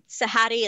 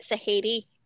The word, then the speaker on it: Sahady: There's three ways to pronounce my name, right? Sahady is like the phonetic normal way. And then you can get Sahady. And I always tell people I'm not a hater. And then my favorite, which only happens like 1 in 10 times, is Shady. They just dropped the first day entirely.